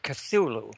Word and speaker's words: Cthulhu: Okay, well, Lovecraft created these words because he wanted to kind of give trouble to the human vocal cords, because we can not only not understand them, but we also can't even pronounce them. I pronounce it Cthulhu.